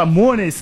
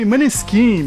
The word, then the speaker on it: Måneskin: A gente fala Mones, que é o nome oficial com a pronúncia italiana, mas aqui no Brasil o pessoal chama de Maneskin mesmo, daquela música do Begin, que toca aí todo o rolê, toda a balada.